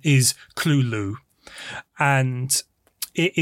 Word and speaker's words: Cthulhu: And, like, Yoshi insists that Cthulhu should be pronounced Cthulhu. And that is how it's pronounced in this film as well by Russ's dad, Cthulhu.